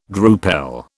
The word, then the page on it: drupel: British English